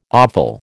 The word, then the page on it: offal: American English